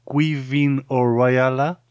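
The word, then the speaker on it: Caoimhín Ó Raghallaigh: Agradecemos a Quivin O'Riala que haya puesto en su web precisamente más o menos cómo se pronuncia su nombre, porque se escribe Caoimhin o Ragalaig, así que ya veis, el gaélico irlandés es bastante complicado a la hora de pronunciarlo.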